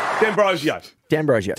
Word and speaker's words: D'Ambrosio: For D'Ambrosio.